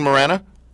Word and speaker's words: Morana: Julian Morana? – Julian Morana, that is correct.